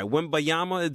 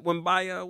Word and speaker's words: Wembanyama: They want to get that guy, Wembanyama. Did I say his name correctly, Shep? Wembanyama, what's his name from France? – Sounds right.